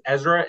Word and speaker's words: Ezra: So my first name is Ezra. It's spelled E-Z-R-A.